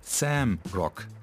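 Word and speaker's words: Zamrock: Carl Hector Presents Johnny ist ein Projekt, das es sich zur Aufgabe gemacht hat, dem Sam Rock zu huldigen. Sam Rock ist der in Vergangenheit geratene Rocksound Sambias. Geschrieben wird er mit Z, denn im Englischen schreibt man Sambia mit Z. Sambia also.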